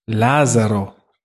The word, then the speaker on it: Lazaro: Lazaro.